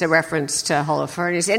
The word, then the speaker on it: Holofernes: Yeah, by cutting off the head of Holofernes.